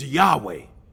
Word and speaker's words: Yahweh: And the way that this is pronounced is Yahweh.